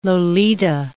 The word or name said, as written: Lolita